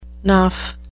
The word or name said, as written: naff